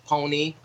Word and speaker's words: Ponyc: And I'm a solo artist, but I'm also part of a group that just dropped an album called Pony. It's spelled P-O-N-Y-C. The C is silent.